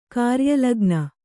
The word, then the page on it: ಕಾರ್ಯಲಗ್ನ: Kannada